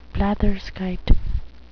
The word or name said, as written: blatherskite